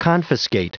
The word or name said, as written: confiscate